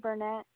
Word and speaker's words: Burnett: Kylie Burnett. – Can you spell that for me? – K-Y-L-I-E. B-U-R-N-E-T-T.